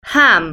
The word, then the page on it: ham: British English